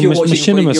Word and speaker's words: machinima: Like, oh, is it like a machinima sort of thing? – Like, so, like if you're watching your pay-per-views.